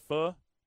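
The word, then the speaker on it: phở: Main Gate Square, Main Gate Plaza, if you will, right next to Saigon Pho, or Pho, if you're being, you know, proper with the pronunciation of it.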